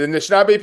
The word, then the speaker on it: Anishinaabe: Then the Anishinaabe people, it's part of my heritage.